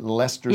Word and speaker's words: Leicester: The Sleaford Mods, who are coming up later, confirmed that we shall be pronouncing it Leicester Square. – Yeah, Leicester. We don't have to mess around in there. – There's all kinds syllables. – Of extra letters in there.